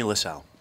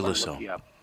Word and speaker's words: Lissow: The comedian, Jamie Lissow. – Oh, yeah. – Or Lissow. Come on. – How do you spell that? – It's L-I-S-S-O-W.